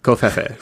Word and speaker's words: covfefe: Covfefe.